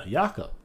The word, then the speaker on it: Jakob: Jakob. – And he doesn't pronounce the J.